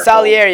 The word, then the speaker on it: Salieri: Yeah, no, like I enjoyed that, and then the maid that Salieri places in their Salieri, home. Most Solari, yeah.